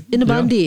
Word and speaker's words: innebandy: Oh, it is Innebandy.